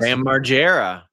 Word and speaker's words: Margera: And then there were other people there, like Kid Rock, Theo Vaughn, Bam, do you spell his last name Margera? Or do you pronounce it Margera, the guy from Jackass? – Bam Margera. – Margera.